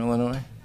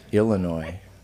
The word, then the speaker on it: Illinois: You know how you can tell someone who's not from Illinois? – How? – They pronounce it Illinois.